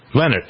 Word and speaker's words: Leonhart: Did I pronounce her name? L-E-O-N-H-A-R-T. Leonard? Leonard, I think, probably.